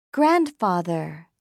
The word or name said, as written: grandfather